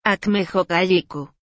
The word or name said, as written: Âkmejokajyççu